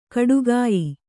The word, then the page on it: ಖಡಗಾಯಿ: khaḍagāyi